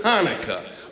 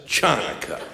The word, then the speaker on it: Hanukkah: So I was stuck with Hanukkah, or as many still pronounce it in the Midwest, Chanukkah. Or, as it's pronounced in the Deep South, Chinookah.